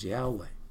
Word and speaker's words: Yahweh: Ahava is a Hebrew word for love, and it sounds an awful lot like some people pronouncing Yehovah, although the most accurate pronunciation is Yahweh.